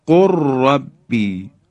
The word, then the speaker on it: قل ربی: خب، یا در حرف لام، لام ساکن در حرف را، دقت کنید، قل ربی، قل ربی، لام ساکن در حرف را ادغام میشه، مثل قر ربی، قر ربی. بی دیگه لام رو ما تلفظ نمی کنیم، بلکه حرف را مشدد بیان میشه.